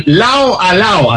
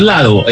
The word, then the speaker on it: Lao a Lao: le pertenece a Prince Royce, la canción Lao a Lao. Así se escribe, no es Lado, es Lao a Lao.